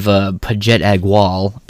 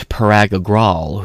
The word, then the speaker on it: Parag Agrawal: And you're going to have Pajet Agwal, purposely mispronounced his name too. It's Vagata is her name. And it's Parag Agrawal, who's the CEO, and Vagaita is the, like, HR algorithmic bitch.